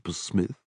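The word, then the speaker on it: Psmith: "I don't know whether I can say that, Mr. Pursmith. The P is silent, as in Pshrimp," he reminded her.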